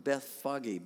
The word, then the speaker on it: Bethphage: It's pronounced Bethphage by the people of the Middle East there.